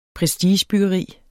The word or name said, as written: prestigebyggeri